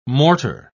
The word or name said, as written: mortar